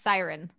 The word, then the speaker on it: Siren: Siren is pronounced Siren. I read it wrong.